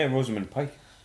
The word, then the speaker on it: Rosamund Pike: Rosamund Pike? – Yeah, Rosamund Pike.